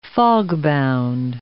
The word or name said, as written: fogbound